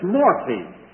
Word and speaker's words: morphe: It's morphe. M-O-R-P-H-E. And so long E.